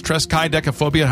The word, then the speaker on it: triskaidekaphobia: But it is triskaidekaphobia, how it's pronounced.